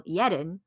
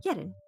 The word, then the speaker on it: Yehdinh: You can find me on Instagram as well as Twitter by my handle, Yedinh. That is spelled Y-E-H-D-I-N-H. I swear that's how it's spelled. again, you can find me on Instagram and Twitter under the name Yedinh.